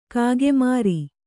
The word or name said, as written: ಕಾಗೆಮೋರೆ